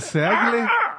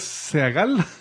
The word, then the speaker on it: Seagle: No, este es Seagull. – Este es Seagle. El otro es Seagal. Este es Seagle.